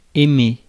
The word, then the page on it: dig: /dɪɡ/